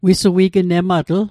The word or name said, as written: wissuignematl